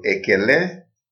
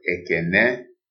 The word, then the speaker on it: ekele: So ekele is the proper way to say it in written Igbo, but ekene is a dialect.